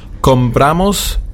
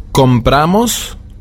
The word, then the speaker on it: compramos: Bitte achten Sie auch auf die Betonung. Wir haben compro, compras. Compra, auf dem O betont. Compramos, comprais, auf dem A. Und compran, wieder auf dem O. Und das gilt für alle Verben. Es ist immer dieser Schleier drin in der ersten und zweiten Person Plural. Ja, dieses compramos und comprais. Es wird immer auf der letzten Silbe betont, wobei die anderen immer auf der ersten Silbe betont werden.